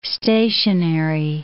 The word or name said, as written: stationary